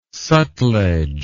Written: Sutlej

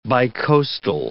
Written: bicoastal

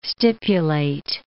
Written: stipulate

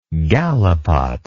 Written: galipot

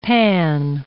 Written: panne

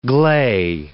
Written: gley

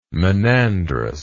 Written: monandrous

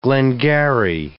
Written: glengarry

